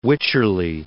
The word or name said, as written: Wycherley